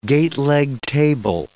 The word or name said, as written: gateleg table